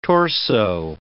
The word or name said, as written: torso